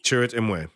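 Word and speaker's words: Chirrut Îmwe: You have So how do you pronounce Donnie Yen's character? Is it Chirrut Imwe? – Chirrut Imwe. Yeah.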